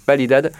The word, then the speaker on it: Validad: Validad, ça s'écrit avec un V mais on prononce B, donc Validad, qui travaille pour donner du grain à moudre sur la dynamique gasconne sur Mont-de-Marsan et sur son aglo.